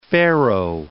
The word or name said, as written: Pharaoh